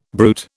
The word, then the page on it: brute: British English